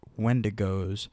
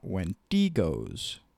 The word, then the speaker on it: Wendigos: Yes, I will be pronouncing it Wendigos, not Wendigos.